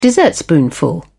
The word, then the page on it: dessertspoonful: British English